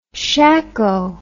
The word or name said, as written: shacko